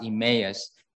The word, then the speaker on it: Emmaus: In their disappointment, they left Jerusalem and they left other disciples and going their separate way.To Emmaus. – 当时他们心里充满了失望，所以他们离开了其他的使徒，他们向以马忤斯去。 – Now English is pronounced Emmaus, but if you do read it from Greek, it's pronounced Emmaus, which is like Chinese pronunciation.